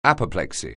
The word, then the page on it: apoplexy: anglais